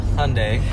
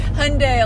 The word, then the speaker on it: Hyundai: I drive a 2013 Hyundai Elantra. Did I pronounce it right? – Hyundai. – Hyundai Elantra, yes.